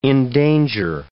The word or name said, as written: endanger